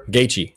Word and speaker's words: Gaethje: But after the fight was over, his competitor, I can't pronounce his name, Gaethje? Well, whatever. – Gaethje, yeah, you're talking about Gaethje.